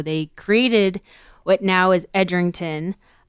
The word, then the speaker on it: Edrington: Edrington.